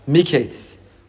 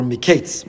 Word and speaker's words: Miketz: Page 222 in the Art Scroll Stone Chumash, the privilege of reading Parshas Miketz, or Miketz, more appropriately pronounced.